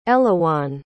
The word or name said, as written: Elouan